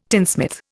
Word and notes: tinsmith: Indian

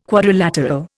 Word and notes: quadrilateral: Indian

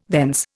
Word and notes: thence: Indian

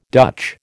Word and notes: Dutch: American English

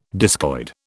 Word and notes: discoid: British English

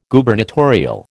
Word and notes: Gubernatorial: American English

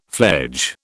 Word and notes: fledge: British English

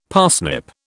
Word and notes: parsnip: British English

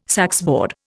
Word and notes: saxboard: Indian

